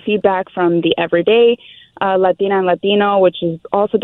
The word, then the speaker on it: Latine: Latin A.